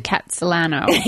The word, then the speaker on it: Kitsilano: I pronounce it Kitsilano. – Yeah. That's, I think, the more traditional way of mm-hmm. pronouncing it.